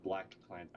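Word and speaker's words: BlacKkKlansman: I believe it's pronounced Black Klansman. I was messing around calling it Black Land.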